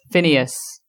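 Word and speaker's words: Finneas: Phineas.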